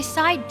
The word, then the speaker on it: sigh: 下个表情，叹气，sigh，啊，叹气呢，它的英文呢就是 s i g h，sigh。在这里呢，gh 不发音，i 发本身的字母音，表示的是疲惫、失望或是不开心的意思。来听这个句子，He sighed deeply at the thought。